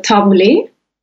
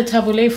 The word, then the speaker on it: tabbouleh: I would say the tabbouleh.